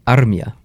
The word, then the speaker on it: Armia: But his last name is actually pronounced Armia.